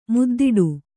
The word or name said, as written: ಮುದ್ದಾಡು